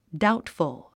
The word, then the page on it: doubtful: dáutfəl; ダウトフゥ